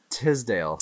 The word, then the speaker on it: Tisdale: You know, like Ashley Teasdale. – Tisdale. – Tisdale? I say Teasdale. I had neighbors. Their names were spelled the same, but they pronounced it Teasdale.